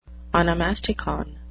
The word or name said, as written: onomasticon